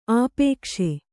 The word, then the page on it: ಆಪೇಕ್ಷೆ: āpēkṣe